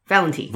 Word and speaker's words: Valente: Valente. It's not Valente. I looked it up. – Okay. – That's a common mispronunciation.